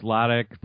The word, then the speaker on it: Lotich: It's Lodic, but you were close enough, and so well done.